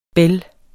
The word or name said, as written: bel